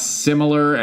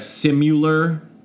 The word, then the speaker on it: similar: People who pronounce similar as simular. Yeah, that's not good.